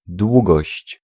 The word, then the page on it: długość: [ˈdwuɡɔɕt͡ɕ]